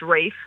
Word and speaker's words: Ralph: Like, the guy who made that Brexit comment that you mentioned there, his name is Ralph, but it's pronounced Rafe, and she's very pointed about that.